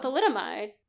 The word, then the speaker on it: thalidomide: Oh, thalidomide.